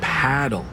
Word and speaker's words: Padel: Wait, is that Padel? – No, that's paddle. Rhymes with saddle.